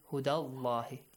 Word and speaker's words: Allah: The word Allah, the letter lam in the word Allah is pronounced heavily because of the...